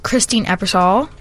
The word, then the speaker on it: Christine Ebersole: Yes, Christine Ebersole. – Oh, okay. – Am I saying that right? – Yeah, that's right.